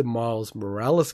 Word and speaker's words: Miles Morales: But it's also really muddied the waters because what they've done is with the Miles Morales game, did I say it right this time? – Yes.